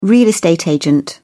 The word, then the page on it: real estate agent: British English